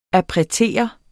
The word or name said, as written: appretere